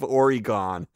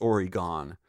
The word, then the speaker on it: Oregon: Oregon. Oh, he says it's not pronounced Oregon. He's one of these people who pronounces it Oregon.